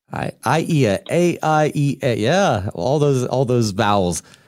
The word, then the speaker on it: Aiea: You got the name right, but it's Aiea. It's so close enough. We're the only, as I mentioned last time, we're the only city in the United States without a consonant, so we can't play Wheel of Fortune.